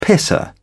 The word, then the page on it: pisser: British English